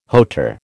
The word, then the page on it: hauteur: American English